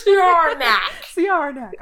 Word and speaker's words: Siarnaq: There's a moon called Syarnak. And it's spelled S-I-A-R-N-A-Q. – It sounds like a villain in DC. – I know. It's Syarnak.